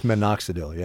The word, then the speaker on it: minoxidil: So it says, I'm going to mispronounce this, minoxidil? – I think it's minoxidil, yeah. – Minoxidil, okay. The active ingredient in Rogaine,